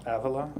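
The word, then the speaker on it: Ávila: Avila. – Yeah. Avila.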